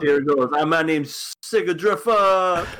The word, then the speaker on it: Sigrdrifa: Let's talk about Warlords of Sigurdrifa. I don't know how to pronounce this damn show's name. – You could. That sounds right, Sigurdrifa.